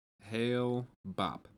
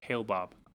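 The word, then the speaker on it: Hale-Bopp: Hillbob? Hellbob? Something like that. – Hail-bop. – Hail-bop. – When you said Hillbob, I typed in H-I-L-L-B-O-B.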